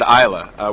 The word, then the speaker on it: Islay: Well, it's spelled, I-S-L-A-Y, but we got to notice that they pronounce it Isla.